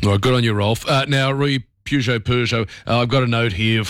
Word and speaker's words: Peugeot: Peugeot, silent T there. In English, Peugeot, Peugeot, from French, Peugeot.